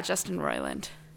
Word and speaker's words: Roiland: Roiland? – Roiland. – Am I saying it right? – Yeah, Justin Roiland.